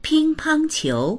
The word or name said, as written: ping1pang1qiu2